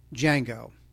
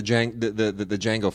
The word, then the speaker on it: Django: Now, you know how Django... – Yes, D is silent.